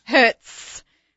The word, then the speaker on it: Hertz: Hertz. – With a Z? – With a Z at the end.